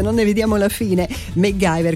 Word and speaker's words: MacGyver: Allora, il Mangio, intanto benvenuto, dice giustamente si pronuncia MacGyver,